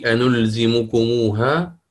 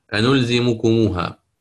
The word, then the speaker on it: أنلزمكموها: فهذه حينها يضاف بين ميم الجمع والهاء واو لفظية وخطية ترسم في المصحف ويتلفظ بها، كقوله تعالى كما قلنا الآن أنلزمكموها، وتكون واو لماذا؟